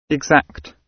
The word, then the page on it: exact: British English